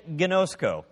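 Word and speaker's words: gnosko (γινώσκω): They're pronounced oida and gnosko.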